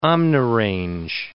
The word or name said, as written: omnirange